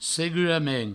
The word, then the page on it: segurament: seguramen